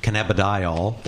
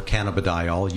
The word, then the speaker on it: cannabidiol: Cannabidiol, or cannabidiol, you hear it pronounced both ways.